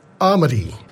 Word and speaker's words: Amaidi: We have a featured album most weeks, and this week it comes from a group called Amadi, A-M-A-I-D-I, pronounced Amadi.